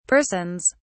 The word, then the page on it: persons: British English